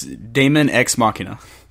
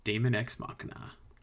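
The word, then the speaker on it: Daemon X Machina: Daemon Cross Machina. – It's Daemon Ex Machina. – Daemon Ex Machina.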